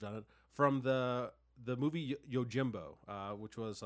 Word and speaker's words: Yojimbo: It's an M, yeah. – Well, no, but it's pronounced as an N. Like, oh, Yojimbo.